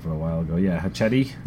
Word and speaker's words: Hachette: Pronounce it like that movie, the Machete. – Machete. – Yeah, which is with an H, just in a very Hispanic Hachette.